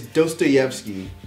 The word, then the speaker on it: Dostoevsky: Even though I love Black Dot, but he mispronounces his name. I thought it was funny. I think he called like Dostoyevsky. It's Dostoyevsky.